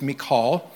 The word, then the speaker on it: Michal: As the Ark of God was entering the city of David, Michal, I know it looks like it's spelled Michael, but the correct pronunciation in Hebrew is Michal.